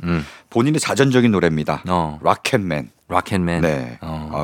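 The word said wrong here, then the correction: Rocket Man: 아, 발음이 좀 락켓맨 그러니까 너무 좀 굴리네요. 아, 로켓맨.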